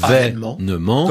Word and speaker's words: événement: Bon, ce mot se prononce donc événement. – Comme avènement.